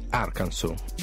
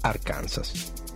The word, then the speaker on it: Arkansas: Quando molti hanno saputo che correttamente si pronunciava così Arkansas e non, come facevamo noi lettori di Tex Willer, Arkansas.